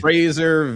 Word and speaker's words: Fraser: First of all, how do you say it? It's the Fraser, like Razor Valley. – Fraser Valley.